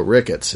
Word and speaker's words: rickets: But Ricketts is just a hard T sound at the end. – Yeah. It rhymes with crickets.